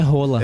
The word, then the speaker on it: Haaland: É, a gente fala Haaland. Todo mundo na Inglaterra também, o pessoal fala Haaland. – Mas o nome é Rola. – É, na pronúncia norueguesa correta é Rola.